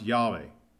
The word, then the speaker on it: Yahweh: In Hebrew, it's pronounced Yahweh. Okay, Yahweh or Yahweh, it's depending on which scholar you listen to.